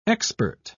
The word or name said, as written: expert